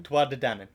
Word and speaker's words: Tuatha De Danann: Tuatha De Danann. Yeah, exactly. – Danann like the company. – Pronounced the same way, spelled differently.